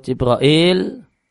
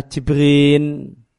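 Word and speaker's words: Jibril (جبريل): Di sini dicontohkan itu Jibril, Perlu diketahui bahasanya Jibril itu cara bacanya ada banyak. Ada kurang lebih 10 cara baca. Yang terkenal di tempat kita Jibril. Cara baca yang lain Jibril. Dan di antara cara baca yang lain adalah Jibrin. Jibrin itu sama dengan Jibrin itu sama dengan Jibril.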